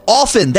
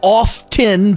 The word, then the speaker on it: often: Often, that's it. It's not often. The T is silent. It's often. I often go out, not I often go out.